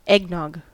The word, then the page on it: eggnog: American English